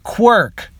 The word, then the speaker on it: quark: We already knew about the Quark Field. Quark Field? – Yeah, Azrella said. You know how your device will disrupt the Quark Field? – Oh, right. Sorry, we pronounce it Quirk, so I got a little confused there.